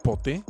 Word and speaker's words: Pote: Vamos a continuar con pote así, P-O-T, acento en la E.